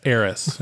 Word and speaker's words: heiress: I was about to say Iris. A spoiled heiress. – Wouldn't have been right. – Yeah. I didn't say it right anyway. – What did you originally say? – Harris? – Like heresy? – Harris. – Why is she Harrison him? – No, it's like, it's heiress.